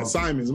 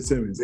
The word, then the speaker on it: Simons: Simons. – My fault. I said Simmons.